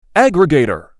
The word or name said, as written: aggregator